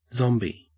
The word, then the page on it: zombie: Australian English